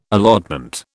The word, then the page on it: allotment: British English